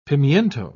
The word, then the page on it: pimiento: British English